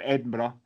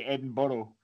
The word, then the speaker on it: Edinburgh: One thing that I'm glad, just going back a little bit there, Joel, is you said the correct pronunciation of Edinburgh, not like the Americans who say Edinburgh, and it's fucking annoying.